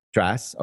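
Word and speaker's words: Tracz: But it's got music and lyrics by Joe Iconis and a book by Joe Trace, T-R-A-C-Z. I'm not sure how to say his name. – Trace.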